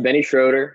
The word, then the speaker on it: Schröder: Yep, Benny Schroeder.